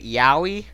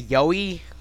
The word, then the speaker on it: yaoi: I say yaoi. I used to say yoi because I didn't know how to say it.